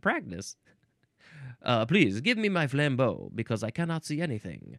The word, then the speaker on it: flambeau: Actually, the emphasis is on the first syllable, flambeau. Like Rambo? Rambo covered in cognac and lit on fire? This is French, so it's spelled F-L-A-M-B-E-A-U.